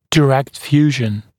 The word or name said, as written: direct fusion